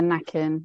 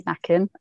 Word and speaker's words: Nacken: Yeah, Nacken. That's I think it's pronounced as Nacken.